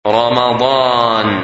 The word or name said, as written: Ramadan